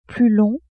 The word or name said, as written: plus long